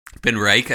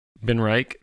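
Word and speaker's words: BenRiach: Joel and M09 said, Ben Riach. How do you say that? – Ben Reich, I think. – Ben Reich, 10 is one of his go-tos.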